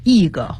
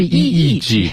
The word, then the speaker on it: EEG: optics 这个词呢是讲这个学科，那么 optical是它的形容词，光学的。那如果你去国外你要配眼镜的话，你看见那个 OP 开头的一个单词挂在这个商店的大门那儿，你就知道了，这个就是跟眼镜有关系的，和光学有关系的。好， MRI 这个单词呢有的同学说我能不能念成那个 Marie 啊，没有这么念的，它就是每一个字母单独念出来，所以是 MRI 核磁共振成像。那我们之前也讲到了一个 EEG，也是单独去念啊，不能念成什么 EG 或者 EG。有的同学说索性那就是一想哎不对，那 EG 是 EGG 啊，那个医学的这个是 EEG，但是看上去挺像的。